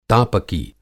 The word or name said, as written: तापकी